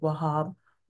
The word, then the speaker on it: Wahab: I'll say the word Wahab, Wahab, Wahab. So there's a little emphasis on the hab, okay?